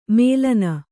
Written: ಮೇಲನ

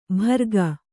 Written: ಭರ್ಗ

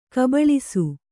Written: ಕಬಳಿಸು

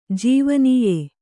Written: ಜೀವನೀಯ

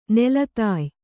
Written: ನೆಲತಾಯಿ

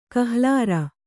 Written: ಕಹ್ಲಾರ

